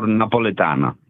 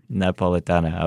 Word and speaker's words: Napoletana: Again, we're talking about pinsa, which is different from Napoletano. Am I saying that right? Napoletano pizza? – You're very 90% close. Neapolitan pizza or Napoletana? – Napoletana. Okay, good.